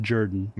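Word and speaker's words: Jordan-Hare: Spelled Jordan, pronounced Jordan.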